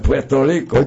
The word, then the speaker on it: Puerto Rico: It's Puerto, it's Spanish, Puerto Rico.